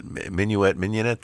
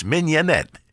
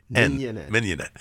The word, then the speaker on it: mignonette: The minuet thing? Go ahead. – Minuet. – No, that's the dance. Mignonette. – Mignonette.